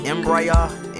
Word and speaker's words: Embrya: Embrya. I think that's how you pronounce it. Embrya. Yeah. Like embryo, but put a A at the end.